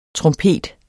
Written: trompet

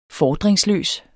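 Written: FORDRINGSLØS

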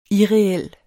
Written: irreel

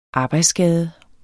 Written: arbejdsskade

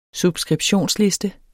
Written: subskriptionsliste

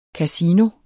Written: CASINO

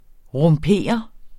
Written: rumpere

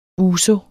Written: ouzo

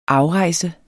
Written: afrejse

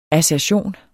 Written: assertion

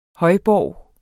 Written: højborg

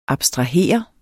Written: abstrahere

